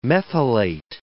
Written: methylate

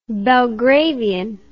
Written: belgravian

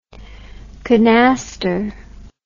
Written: kanaster

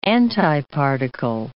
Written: antiparticle

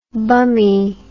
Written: bummy

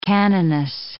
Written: canoness

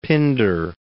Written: Pindar

